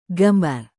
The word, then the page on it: gambar: Indonesian